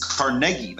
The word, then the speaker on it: Carnegie: Okay, Andrew Carnegie, one of the richest men to ever live. It's Carnegie. That's how you pronounce his name.